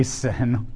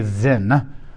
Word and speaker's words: Sin: Now, that's just phonetically coincidental. that does not mean this is the desert where they sin or that sin thrives here. It's kind of like S U N and S O N are pronounced exactly the same but mean two different things.